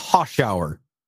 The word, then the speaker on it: Hoschouer: It's Hoschouer.